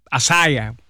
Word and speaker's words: Isaiah: Porque la I en inglés se le dice I, y entonces pues Asaya, aunque nosotros en español es más fácil Isaías.